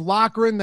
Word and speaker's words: Loughran: It's Loughran.